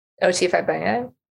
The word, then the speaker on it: Fagbenle: O.T. Fagbenle.